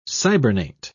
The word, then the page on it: cybernate: British English